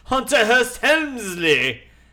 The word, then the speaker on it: Hunter Hearst Helmsley: It's Hunter Hearst Helmsley.